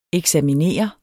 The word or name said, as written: eksaminere